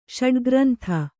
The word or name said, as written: ಷಡ್ಗ್ರಂಥ